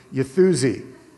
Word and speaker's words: Uffizi: That one, Uffizi.